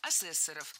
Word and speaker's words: ассессоров: При Московском университете был организован суд, свой корпоративный, в составе одного профессора, двух ассессоров, который там разбирался вот с этими мелкими разными студенческими шалостями. – Ассессоров. Неверно. Правильно, ассессоров. Существительное «ассессор» имеет неподвижное ударение на втором слоге.